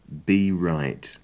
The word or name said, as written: be right